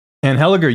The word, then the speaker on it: Anheliger: And they've got a fight now coming up featuring a Canadian in Chad Anheliger. – Anheliger, you got it. Yep.